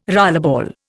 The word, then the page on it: rollerball: Indian